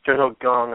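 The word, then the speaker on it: Jung-ho Kang: So Jung-ho Kong is a guy, or Jung-ho Gong, I believe is actually the way you pronounce it, is a guy to keep an eye out for.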